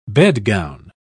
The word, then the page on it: bedgown: British English